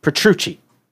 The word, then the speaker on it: Petrucci: Petrucci? How do you say that? – Petrucci.